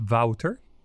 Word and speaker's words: Wouter: My name is Wouter.